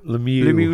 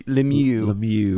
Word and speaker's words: Lemieux: Kayla Lemieux, am I pronouncing that Lemieux? – Lemieux. – Lemieux.